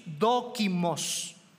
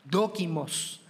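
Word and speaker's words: dokimos (δόκιμος): La palabra aprobado es una palabra griega que, literalmente se dice así, dokimos, dokimos.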